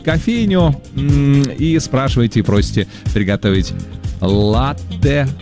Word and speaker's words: латте: И знаете, это правильно, потому что слово итальянское, ударение там стоит на первый слог, латте. И вот, несмотря на обилие гласных, произносится твердо, без мягкости, латте.